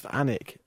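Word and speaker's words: Alnwick: I think it's pronounced Annick in the north of England.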